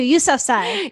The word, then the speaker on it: Yousafzai: Is it Yousafzai? Thank you, Yousafzai.